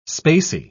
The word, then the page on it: spacy: British English